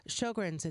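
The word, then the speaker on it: Sjögren's: Sjogren's, it's spelled S-J-O-G-R-E-N, but it's pronounced like show your grin, Sjogren.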